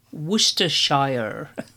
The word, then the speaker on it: Worcestershire: Now, for our American listeners, that's pronounced Worcestershire. Anyway, lockdown happened, in-person drum classes stopped, and I then assisted James in completely converting his in-person classes to online.